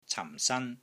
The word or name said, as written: cam4 san1